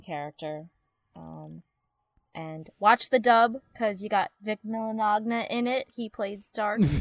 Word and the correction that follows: Mignogna: Wait, how do you say his name? – It's Vic Mignogna.